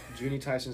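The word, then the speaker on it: Juni Taisen: How do you say that without stuttering? Junie Tyson Zodiac War, right? – Yeah. – Yes, sweet.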